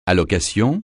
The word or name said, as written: allocation